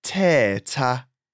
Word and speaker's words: thetha: The letter th is similar it would be pronounced t, not th like in English. An example is the word for talk in Xhosa, thetha.